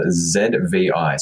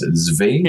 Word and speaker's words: Zvi: I've read a couple of pieces, which have been certainly eye-opening, but it is a blogger called ZVI. So I believe it's pronounced Zvi. – Yeah, I think it's Zvi.